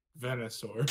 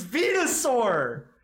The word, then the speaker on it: Venusaur: Pronounce that again. – Venusaur. – Please tell me you're joking right now. Please tell me you're doing this for a bit. Please tell me you're mispronouncing it on purpose. – Venusaur. – Please. It's Venusaur.